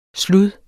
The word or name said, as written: slud